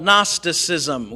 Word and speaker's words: Gnosticism: The common teaching in the first century was called Gnosticism. We talked about that a little bit last week. It has a silent G at the beginning of it, and it comes from the Greek word meaning knowledge.